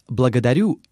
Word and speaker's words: благодарю: To reproduce this Russian consonant R in благодарю, you should roll your R's. The Russian R resembles to Italian or Spanish R.